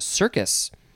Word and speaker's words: cercus: Okay, next is, I think it's just called or pronounced circus, but it is spelled C-E-R-C-U-S, not the circus with the clowns.